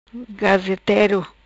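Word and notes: Gazetero: patua

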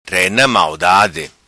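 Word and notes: Rena Maldade: patua

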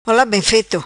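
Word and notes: Ola Bem Feto: patua

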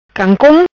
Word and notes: Cancom: patua

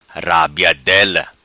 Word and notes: Rabiadela: patua

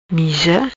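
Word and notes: Miza: patua

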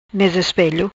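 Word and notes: Ispelo Mesa: Patuá

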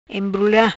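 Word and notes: Embrula: Patua

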